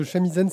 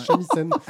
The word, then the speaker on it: shamisen: Ronald propose que le chamisen c'est un félin à moitié calme. C'est pour ça qu'il vaut mieux qu'on prononce bien, qu'on dise shamisen.